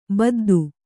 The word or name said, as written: ಬುದ್ಧ